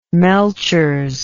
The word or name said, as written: melchers